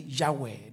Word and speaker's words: Yahweh: So it's actually Yahweh. The Greeks pronounce it Yahweh. It's Y-H-W-H. Yahweh, which is J, German.